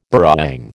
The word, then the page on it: burrawang: American English